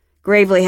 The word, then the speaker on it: Gravely: A gravel road, so growing up there'd been jokes about that, even though you pronounced it like grave, not gravel. Gravely had been a makeout spot in high school, somewhere to park on a date.